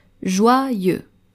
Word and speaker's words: joyeux: The next word, joyeux, joyeux, J-O-Y-E-U-X, joyeux. Ici, vous êtes tenté de lire joyeux, joyeux, mais il faut prononcer joyeux, joyeux comme la joie, happiness. The final X is also silent, joyeux.